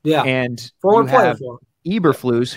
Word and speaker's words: Eberflus: Um, with the Broncos, the Packers OC, uh, Eberflus Am I pronouncing that correct? I believe. Yes, correct.